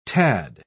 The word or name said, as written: tad